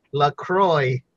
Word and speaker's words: LaCroix: Speaking of which, our new sponsor for Lauderdale's own LaCroix, it's flavored club soda, basically. – It has an X at the end, but you don't pronounce that shit, and I forgot why.